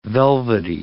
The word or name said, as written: velvety